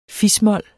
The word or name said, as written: fis-mol